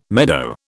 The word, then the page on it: meadow: British English